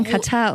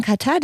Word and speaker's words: Katar: Da braucht man schon gar keinen Katar, um sich dafür zu entscheiden, das nicht zu gucken. Ja. Claudia Neumann ist übrigens just in diesem Moment schon in Katar, denn ich sage übrigens, ich weiß, es ist irritierend, aber ich weiß, alle sagen immer Katar, aber für mich ist es Katar.